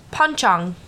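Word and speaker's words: pocong: It's panchang. – Panchang. – Panchang. – I can't say. – It's it's spelt like Poncong, like P-O-N-C-O-N-G, but it's Poncong.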